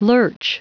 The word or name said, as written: lurch